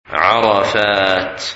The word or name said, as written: Arafat